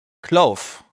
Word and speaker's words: Clove: Wie wird's ausgesprochen? – Clove. Also Clove. Das ist ja Clove.